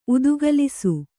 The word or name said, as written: ಉದುಗಲಿಸು